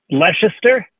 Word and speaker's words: Leicester: Leicester Tigers fan? I'm not sure how the English pronounce that, but Leicester? – L-E-I-C-E-S-T-E-R.